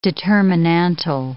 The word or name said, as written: determinantal